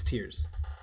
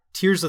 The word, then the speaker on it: tear: Yeah, so Tears of the Kingdom is pronounced tear as in fear, not tear as in pear.